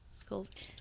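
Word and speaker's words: Scalvert: But also, the whole thing that has also sparked this is she has what's called the Sculver Stone, right? Did I say that right? Sculver. – Yeah, yeah. – I keep wanting to say Shulker, like Minecraft.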